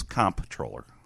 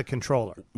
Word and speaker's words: comptroller: Is the P silent in comptroller? – It's comptroller. – With a P. – Yes. – Now, some people pronounce it controller. – As an N. – Yeah. – There is no N in comptroller. – Right, but they pronounce it as controller.